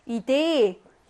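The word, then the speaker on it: Idee: 이데 얘도 발음 자주 나오지. 강세가 여기 있고요. 중모음이네. 중모음이 뭐야? 중복된 모음. 똑같이 생긴 거두개 있으면 중모음이죠. 중모음은 한 번만 길게 발음한다. 그래서 이데니까 이데 요렇게 에 하고 길게 발음하네요.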